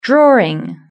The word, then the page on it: drawing: British English